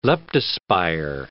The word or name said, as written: leptospire